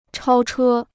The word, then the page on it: 超车: chāo chē